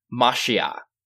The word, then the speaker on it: Mashiach: or to use the more loaded Hebrew, Mashiach, more commonly pronounced in English as Messiah.